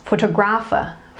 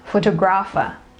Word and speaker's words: photographer: This one I hear people say photographer. Photographer. Yeah, but it is not stressed on the third syllable. It is stressed on the second syllable. And you go photographer.